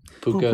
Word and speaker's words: Puka: Then, we just talked about, oh no, I think Pua Nakua. How do you say his name? – Puka. – Nakua. – Nakua. – Pupu Nakua.